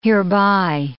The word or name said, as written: hereby